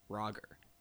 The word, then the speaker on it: Braugher: I actually noticed on IMDb just before this that it says it's pronounced Brogger. Brogger. – The yeah. G is hard.